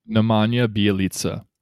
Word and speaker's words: Nemanja Bjelica: How do you pronounce his name again? You know his name. – Nemanja Bjelica.